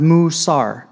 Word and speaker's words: musar: So the Hebrew word that we're going to be seeing on most everything that we look at tonight is called musar, and it's pronounced musar.